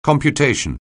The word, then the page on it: computation: anglais